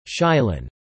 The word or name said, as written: Shilin